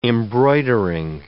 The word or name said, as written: embroidering